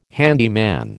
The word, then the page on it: handyman: American English